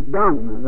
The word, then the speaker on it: Deng: And the head man, Wah, or however you pronounce it, I don't know how he pronounces it, and then there's Vice Chairman Deng, they call it. I think it's spelled like D-E-N-G.